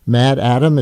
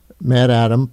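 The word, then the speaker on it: MaddAddam: And you reference your 2003 book, Oryx and Crake, from your Mad Atom, is that how you say it, trilogy? – Yeah. – Mad Atom, plotted around the climate crisis and, of all things, a pandemic.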